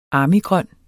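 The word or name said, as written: ARMYGRØN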